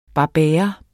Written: barbera

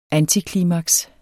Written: antiklimaks

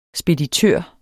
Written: speditør

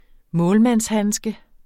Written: målmandshandske

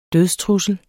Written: dødstrussel

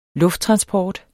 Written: lufttransport

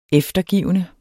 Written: eftergivende

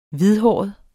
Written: hvidhåret

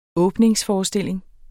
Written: programtype

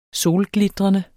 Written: solglitrende

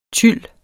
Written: tyl

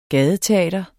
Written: GADETEATER

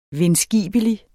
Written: vindskibelig

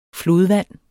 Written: flodvand

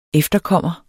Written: efterkommer

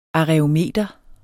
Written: aræometer

